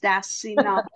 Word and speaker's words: Stasinopoulos: It is by, I am going to say her name, last name wrong. She's Greek. Agape is her first name. Her last name is Stasinopoulos. Stasinopoulos. – That's very close.